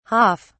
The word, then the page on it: haaf: British English